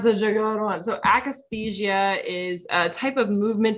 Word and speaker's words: akathisia: I want to make sure I say it right. Akathisia. Is that how I say it? Yeah. Mm-hmm.